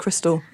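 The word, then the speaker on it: Xtal: I've never, ever understood that that's pronounced Crystal until you've just said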